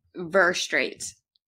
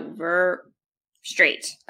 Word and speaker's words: Verstraete: Verstraete. – Verstraete. – Yeah. So, Verstraete, like a straight line.